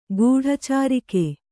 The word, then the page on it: ಗೂಢಚಾರಿಕೆ: guḍha cārike